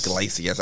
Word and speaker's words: Iglesias: Iglesias.